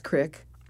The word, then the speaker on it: creek: It was really healing to be there and to have adventures and have people know that C-R-E-E-K is Crick. And you can spell it Creek, but nobody says it that way.